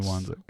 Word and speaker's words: Wanzik: You can also listen to local stories from Clark Adamitis, also known as Judy Pope, and Jamie Wance. I think, did I say that right? – Wance. Jamie Wanzik. – Wanzik, there you go.